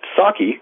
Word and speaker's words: psake: So, it's pronounced Saki, P-S-A-K-E. It's a silent P.